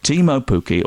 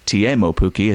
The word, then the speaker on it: Teemu Pukki: He has scored, Timo Pukki, or Teemu Pukki, as he's told us it's now pronounced, he has scored in each of his first three Premier League games.